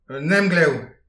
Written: un emglev

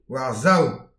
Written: war-sav

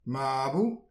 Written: mabou

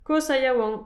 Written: kozh ha yaouank

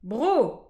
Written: bro

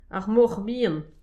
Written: ar moch bihan

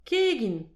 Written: kegin

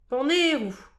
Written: panerou

